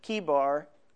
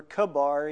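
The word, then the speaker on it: Chebar: Kibar, or Kabar, either way, and it's pronounced both ways, that the heavens were opened and I saw visions of God.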